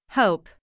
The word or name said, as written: hope